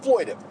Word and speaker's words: exploitive: because it's by its very nature an exploitative. Am I saying this right? It's exploitive. It's an exploitive genre.